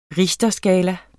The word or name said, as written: richterskala